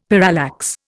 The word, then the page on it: parallax: Indian